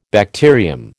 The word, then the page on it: bacterium: American English